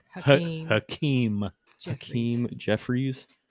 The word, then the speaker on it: Hakeem Jeffries: Hakeem Jeffries had a 212 vote.